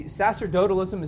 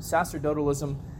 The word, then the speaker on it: sacerdotalism: And I want you to see sacerdotalism is how you pronounce that first word, sacerdotalism.